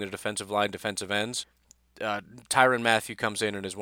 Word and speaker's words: Tyrann: and they say you're supposed to say it like Byron, but it's with a T. That's what Google says, so his name is now Tyrann.